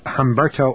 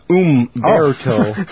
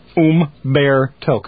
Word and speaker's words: Humberto: I got Humberto Ortega. – Yeah, but it's pronounced Umberto. – Oh. Showing my ignorance. – O-O-M-Bear-to. Umberto. Come on, let's read it together.